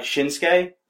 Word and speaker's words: Shinsuke: Like Shinsuke. There's the U between the Shin and Ske. – Yeah, but it's like a silent U.